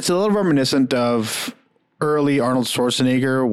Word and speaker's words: Arnold Schwarzenegger: Well, Arnold Schwarzenegger still has a, I know that's how you pronounce it. It doesn't sound right though.